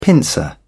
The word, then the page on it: pincer: British English